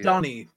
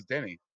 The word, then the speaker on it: Denis: Donnie, but yes. – Donnie, okay. – Donnie Villeneuve, yeah. – Okay. I thought it was Denny.